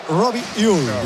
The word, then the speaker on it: Robbie Ure: A ver, el club pasó incluso un audio, lo ha pasado. Y en concreto sería Robbie Ure. – Qué bien lo pronuncia.